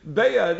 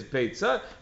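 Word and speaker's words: beitza (ביצה): So that's why, says the Tiferes Yisrael, that's why some pronounce it beya, not beitza, to lafuke any sort of michshol like happened in that situation. Nevertheless, the real word in Hebrew is beitza, and my rebbeim always said beitza.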